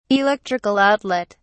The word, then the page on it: electrical outlet: British English